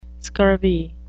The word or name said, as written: scurvy